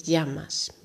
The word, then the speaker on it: llamas: In Argentinië bijvoorbeeld is het uh, como te llamas je schrijft het hetzelfde, maar je, het wordt daar meer als je uitgesproken. En in Spanje is het como te llamas. Me llamo Lloret de Mar.